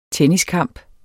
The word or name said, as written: tenniskamp